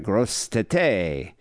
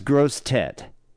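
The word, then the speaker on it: Grosse Tete: You know, I thought I wasn't pronouncing it properly. Grossettete. Yeah, I thought it was like Gross Tete. But no, it's Gross Tit. So it sounds like gross tit, which means big head in French.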